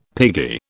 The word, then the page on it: piggy: British English